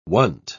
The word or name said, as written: wont